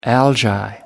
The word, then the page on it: algae: British English